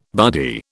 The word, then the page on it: buddy: British English